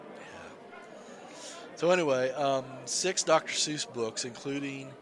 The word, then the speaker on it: Seuss: And I think that Dr. Seuss, I think it's like, accurately is pronounced Seuss is pronounced like choice, so like the correct pronunciation, I think, is supposed to be Dr. Seuss, but that's beside the point.